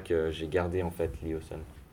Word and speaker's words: Lyosun: Alors, Léoson, ça s'écrit L-Y-O-S-U-N, donc c'est un petit peu bizarre. Il y a des gens qui peuvent le prononcer Léoson aussi. Moi, j'ai décidé Léoson parce que je trouve que c'est plus, ça sonne mieux, en fait, et c'est plus facile à prononcer aussi.